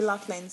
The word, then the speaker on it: Loughlin: C'est Loughlin, ça se prononce Loughlin.